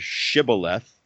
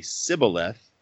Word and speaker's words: shibboleth: It's uh, ask them to say the word shibboleth, and if they can't say it right and they say sibboleth, they're gone.